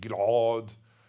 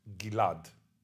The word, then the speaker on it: Gilad: My name Gilad was like that pronounced in Hebrew. Nowadays everybody pronounces it Gilad. A little bit like Julia Gilad, you know, Gilad. And some Aussies write it with an R, Gilad, you know, as in Gilad.